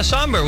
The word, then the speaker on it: sombr: Somber, S-O-M-B-R. – Oh, yeah, Somber.